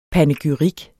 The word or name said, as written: panegyrik